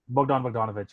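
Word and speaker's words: Bogdan Bogdanović: Bogdan Bogdanovic, yeah.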